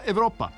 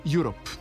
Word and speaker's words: Europe: Pa trebalo bi Deep Purple, ali ako je Deep Purple, onda bi ovo mogla da bude grupa Evropa. No nije, jer je Europe.